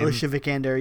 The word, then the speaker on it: Alicia Vikander: It kind of launched Alicia Vikander. Is that how you pronounce her name? – Alicia Vikander, yeah.